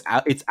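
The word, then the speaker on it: août: August in French. Oh, it's au. Au. Remember? The A-O-U with the hat and teeth? Au. – The hat. – Au.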